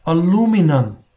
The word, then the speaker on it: aluminum: 好，最后还有一个词，我当时找了一个词也挺有意思的，也可以说明这个问题，就是美国人他那个吞音啊，比如说像铝合金这东西啊，美国人叫 aluminum。 aluminum，它那个 n i u m 呢就没有发 i 的音了，叫 aluminum。但是呢，澳式发音呢叫 aluminium，aluminium，这是有差别的。 aluminum 和 aluminium 是不一样的，所以大家去感受一下。